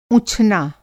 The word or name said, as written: ऊँछना